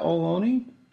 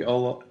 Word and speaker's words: Ohlone: Is that how you pronounce that, Ohlone? I always think Alone.